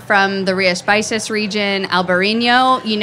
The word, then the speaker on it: Rías Baixas: Rías Baixas, it's spelled RIASBAIXAS.